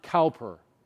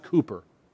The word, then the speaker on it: Cowper: If you saw his name, you would pronounce it Cowper, C-O-W-P-E-R, but it's not pronounced that way. It's pronounced Cooper.